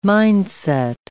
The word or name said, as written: mind-set